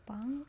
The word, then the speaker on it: Yaobang: It is Yaobang.